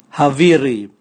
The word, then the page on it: haviri: Raga